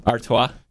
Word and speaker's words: Artois: My traditional thing that I always do around the Christmas time is drink Stella Artois. Did I say that right? Stella Artois? – Artois.